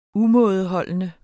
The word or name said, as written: umådeholden